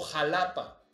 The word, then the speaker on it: Xalapa: Pero los mexicanos, pues nos quedamos con la grafía X y con el sonido, perdón, con el sonido de J, pero con la escritura del sh. Entonces, es por eso que ahora en México pasan cosas como la colonia Joco y se escribe Xoco, o Xalapa y se escribe Xalapa, ¿no? Entonces el Xalapa o el Oaxaca, pues es Oaxaca.